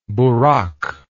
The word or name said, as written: buraq